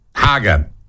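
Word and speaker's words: Hager: Haga. Rhymes with lager.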